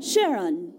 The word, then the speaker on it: Charon: Hey, is it pronounced Chiron or Charon? – Charon, when you're referring to the moon.